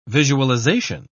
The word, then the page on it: visualisation: British English